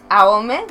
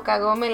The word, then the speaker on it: Kagome: Está horrible, o sea, está hecho con las patas porque para empezar es Aome, no Kagome en las películas.